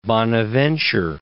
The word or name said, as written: Bonaventure